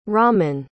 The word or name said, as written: Raman